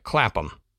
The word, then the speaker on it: Clapham: And to my American sensibilities, I would say Clapham. But I know that's not the way that it's pronounced in the UK.